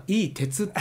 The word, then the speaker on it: IT: 世の中の IT の時代って言いますが、こちらでは IT はいい鉄って読むんですね。